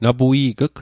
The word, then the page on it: napui'g'g: Mi'gmaq